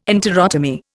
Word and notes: enterotomy: Indian

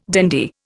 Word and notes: dandy: Indian

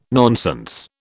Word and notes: nonsense: British English

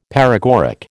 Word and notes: paregoric: American English

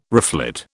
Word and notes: reflet: British English

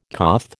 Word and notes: coth: American English

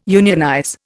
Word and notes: unionize: Indian

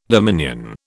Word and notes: dominion: British English

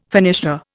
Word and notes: finisher: Indian

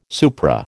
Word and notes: supra: American English

